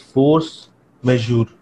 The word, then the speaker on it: force majeure: One is that there is this term which is used quite a lot these days, which is force majeure. You'll have to correct my pronunciation. Okay, your pronunciation is absolutely correct.